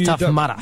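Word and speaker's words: Tough Mudder: Every time I say it, I can't help but pronounce mother. – Mutter. – It's tough mutter.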